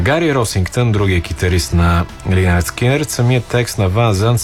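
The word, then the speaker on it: Lynyrd Skynyrd: Ето го и Free Birds, великата песен на една от, разбира се, великите песни на Линард Скинърът през 73-та. Скинърът все още са млада южняшка рок група от Джаксонвил, Флорида. А дебютният му албум носи прочутото заглавие Pronounced Leonard Skinnard.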